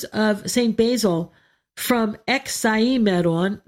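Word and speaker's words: Hexaemeron: The hex, H-E-X. We don't really say the H part in Greek. It's Hexaimeron.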